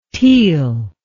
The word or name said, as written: teel